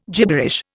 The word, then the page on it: gibberish: Indian